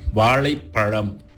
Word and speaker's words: வாழைப்பழம்: சாதாரணமான ஒரு வார்த்தை வாழைப்பழம், இத நான் சரியா உச்சரிக்கிற வரைக்கும் அதை என் வாயில அந்த லா எவ்வளவு தூரம் என் நாக்குக்கு உள்ள போய் அந்த லா சத்தத்தை நான் கொண்டாந்து வெளியே எடுத்து வாழை பழம்